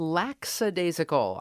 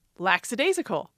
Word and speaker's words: lackadaisical: I'll bet some folks are thinking it's not lackadaisical, it's laxadaisical.